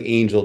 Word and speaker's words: Angle: Pronounced like Angel, just with an extra L at the end.